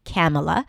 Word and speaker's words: Kamala: Kamala, that's how it's pronounced, Kamala.